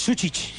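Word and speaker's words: Sučić: Y yo pronuncio mal Sucic, ¿no? – Es Sucic. – Sucic.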